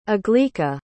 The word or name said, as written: aglaeca